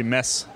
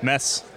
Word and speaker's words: Mads: But sometimes if it's anglicized, it's H-A-A-K-O-N. And sometimes they just spell it that way. – So is Mads Pedersen Mads Pedersen, or is he Mads Pedersen? – It's actually Mess. – It's not, is it? – It's Mess.